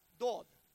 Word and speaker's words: Dod: We would pronounce it Dad, D-A-D. Eldad and Medad. But the Hebrews pronounce Dod.